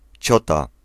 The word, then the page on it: ciota: /ˈt͡ɕɔta/